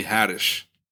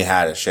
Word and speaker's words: Haddish: Oh, Tiffany Haddish. – Tiffany Haddish.